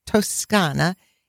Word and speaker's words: Toscana: Allora, on to the region of Tuscany, which is pronounced Toscana in Italian,